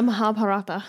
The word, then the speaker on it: Mahabharata: The Mahabharata.